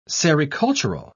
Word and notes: sericultural: British English

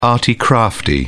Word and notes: arty crafty: British English

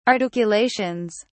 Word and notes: articulations: British English

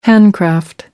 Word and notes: handcraft: American English

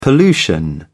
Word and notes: pollution: British English

